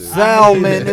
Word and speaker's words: salmon: Salmon, it's an L.